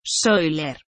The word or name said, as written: Psoiler